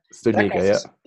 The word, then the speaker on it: Studnicka: Studnicka, yeah.